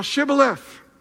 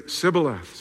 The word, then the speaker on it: Shibboleth: a little pronunciation test here. And they say, "Are you an Ephraimite?" If they say no, then they would say, "Say now Shibboleth." But they would say Sibboleth, so they could tell they were actually Ephraimites.